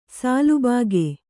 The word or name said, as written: ಸಾಲುಬಾಗೆ